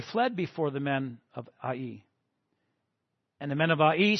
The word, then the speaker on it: Ai: Israel had great confidence that the city of, I'm going to say it, Ai, is how it's pronounced, Ai, was going to be another victory for them.